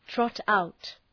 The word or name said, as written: trot out